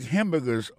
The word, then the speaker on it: hamburger: N'a pas manqué que dans le mot hamburger, ça rend comme d'abdi, il y a trois syllabes là-dedans. Côté appuyé, puis plus ça, c'est sous premier syllabe là.